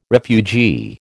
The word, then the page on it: refugee: American English